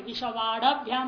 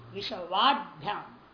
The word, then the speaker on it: विश्ववाड्भ्याम: हम आप बोलते थोड़ा उच्चारण में विश्ववाड़भ्याम नहीं विश्ववाड्भ्याम वाड्भ्याम डहल है डम अ नहीं विश्ववाड़भ्याम ऐसा नहीं उच्चारण होगा विश्ववाड्भ्याम।